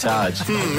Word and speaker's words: Arj: But a comedian we haven't had on is Arj Barker, mainly because we were worried about how Jars would introduce him. – And Jars. – It's Ark Barker. – No, it's not. It's Arj.